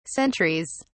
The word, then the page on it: sentries: British English